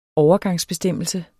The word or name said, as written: overgangsbestemmelse